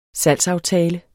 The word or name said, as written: salgsaftale